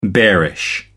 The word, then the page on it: bearish: American English